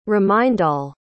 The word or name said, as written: remindal